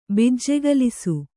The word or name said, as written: ಬಿಜ್ಜೆಗಲಿಸು